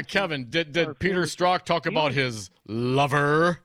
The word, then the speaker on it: Strzok: And it's written by a Peter Sturzok. He was the FBI guy. One of the FBI guys. – Oh, Peter Strzok. – Yeah. – Is that how it's pronounced? – Yeah, I believe so.